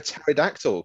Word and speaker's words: pterodactyl: I might go for pterodactyl because of the silent P.